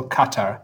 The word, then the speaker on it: Qatar: Q A T A R, pronounced two ways, and I never know how whether to say Qatar or Qatar. What's the, what's the? Uh... – So the stress in the Arabic pronunciation would be on the first syllable. – Yeah. So Qatar.